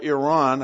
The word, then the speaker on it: Iran: Iran, or Iran, I guess is the proper pronunciation, is the subject of dual judgment prophecies.